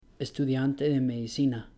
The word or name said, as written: estudiante de medicina